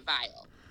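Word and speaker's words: Viall: I'm going it the way that Nick says it. – On so his, he says his own last name, Vile.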